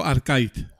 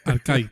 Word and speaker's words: Arkaitz: Primero, eh, cada vez lo pronunciaré de una manera, pero eh, yo, siempre digo Arcait. Arcait. O Arkaitz.